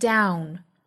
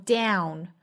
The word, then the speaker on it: down: 这个音呢在美音之中啊，其实唇形更扁，其实更是一个梅花 a 和 a 的过渡 l l own down down, 所以这个词英音和美音也是有明显差别的，英音读成 down down, 美音呢读成 down down, 更压扁一些唇形。